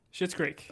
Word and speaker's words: Schitt's Creek: How do you say it? Schitt's Creek? – Schitt's Creek.